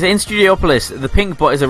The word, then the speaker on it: Studiopolis: So apparently it's Studiopolis. So Studiopolis. Which I never know how to say it, but apparently it's Studiopolis according to the team.